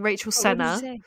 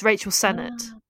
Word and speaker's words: Sennett: I was saying Rachel Senna, but it's Rachel Sennett.